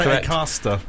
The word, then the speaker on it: Acaster: I was going to say Acaster. – That's how you say it, mate. Say exactly how you just said it.